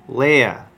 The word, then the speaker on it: Leia: Leia.